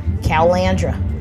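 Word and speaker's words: Caloundra: We did it last Jazz Fest, and we went to Australia last year and did it there at the Calandra Music Festival. Calandra? Yeah, that's how they say it.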